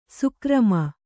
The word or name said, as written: ಸಕರ್ಮ